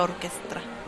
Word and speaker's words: orchestra: Recuerde que cuando pronuncias en inglés algo que tenga una CH es como una K, entonces sería orquestra. – Orquestra, pero yo creo que dice orquestra.